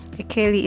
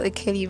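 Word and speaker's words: Ekeli: Akeli is spelled E-K-E-L-I. I feel like you should know how to spell Akeli by now, because I spell it to you like every fucking day.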